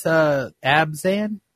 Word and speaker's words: Abzan: If you're, what is Abzan? – Green, black, and white.